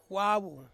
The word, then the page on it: wavu: Ansus